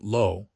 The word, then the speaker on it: Lowe: Figures like Rupert Lowe, pronounced Low, are leaning into algorithmic politics, using social media to drive engagement with inflammatory content.